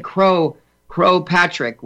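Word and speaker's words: Croagh Patrick: I said Crow Patrick. Well, I actually got that right. That's about how it's pronounced in Gaelic.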